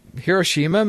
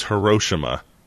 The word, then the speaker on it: Hiroshima: Hiroshima, maybe? – It's Hiroshima, you uncultured swine.